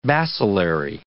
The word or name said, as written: bacillary